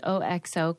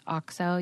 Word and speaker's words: OXO: I read an article about the, well, you might call it the OXO company, but it's actually pronounced OXO.